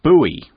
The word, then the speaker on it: buoy: Actually, no, which is why we spell it differently with the B-U-O-Y. OK, in the US, they pronounce it buoy. OK, you may hear it pronounced buoy in the US, life buoy, life buoy.